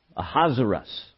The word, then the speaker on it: Ahasuerus: In chapter 1, King Ahasuerus, Ahasuerus is the way that is pronounced, also known as Xerxes, uh, uh, King Ahasuerus uh, gives a seven day feast for all his officials and all his servants.